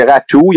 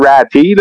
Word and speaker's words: Raty: Ah, ah, ben, selon Elite Prospect, c'est Ratou. Il y en a qui disent Ratty, là, avec, ça s'écrit R-A-T-Y. Moi, de ce que je comprends, c'est Ratou. Mais bon, il va y avoir un débat là-dessus, j'imagine.